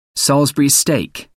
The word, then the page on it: salisbury steak: American English